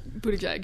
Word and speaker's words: Buttigieg: His Twitter bio, it says like to pronounce boot and then the word egg and then the word egg. – Okay, Buttigieg.